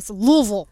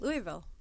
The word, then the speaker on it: Louisville: I mean, my dad's from Kentucky, and I've always called it Louisville, so I don't know. Never had an S. I mean, it's got an S, but I never pronounce it with the S.